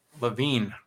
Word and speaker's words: Levine: Levine, much like Adam Levine, not the singer though, and Danella Burnett.